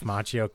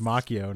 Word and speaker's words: Macchio: And he kept saying it's actually pronounced Macchio. And it's like, it's a guy's name. He gets to choose. – Macchio. – Yeah. Like in Italian, it's Macchio.